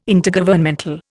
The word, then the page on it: Intergovernmental: Indian